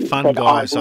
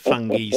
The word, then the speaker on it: fungi: I was going to say fun guys. I thought they were into fun guys, but anyway. But you said fungi, so I thought, well, oh yeah, fungi.